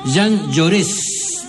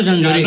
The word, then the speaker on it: Jaurès: La calle de Onces, Jean Jaurès. – Bueno. – No Jean Lloré.